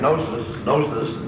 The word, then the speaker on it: gnosis: The Gnostics, by the way, the word Gnostic comes from the Greek word gnosis, the correct pronunciation, which means knowledge.